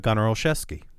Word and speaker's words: Olszewski: You've got Jakobi Meyers and you've got Gunnar Olszewski. Did I say that right? – Yes. – Who's it? – I'm asking James. – Yes. I can pronounce it, you said it right.